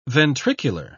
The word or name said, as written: ventricular